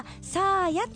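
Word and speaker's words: 咲彩: わ、やっぱ美人が多かった福岡。ちょっとごめんなさい、いいですか先に、うん、今日はマナ娘の誕生日、 8 歳になります、はい、じいちゃんにお誕生日おめでとうコールをしてほしいのです、はい、名前はサーヤと読みます。 – サーヤな、うん、咲く、彩ると書いてサーヤちゃんですね、今時の名前ではありますが、まだじいちゃんでも許してくれる範囲だと思います。どうでしょうかということですね。 – サーヤなんかはあのアメリカ行くといいな。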